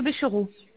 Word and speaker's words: Béchereau: Alors, madame Lucie Le Béchereau. – Béchereau, comme ça se prononce? R-O-T à la fin? – Ah non, E-A-U, pardon.